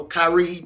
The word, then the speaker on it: Kyrie: No matter what he says publicly about, you know, Kyrie needs to do what's best for him. – I'm sorry, I said his name wrong. Kyrie.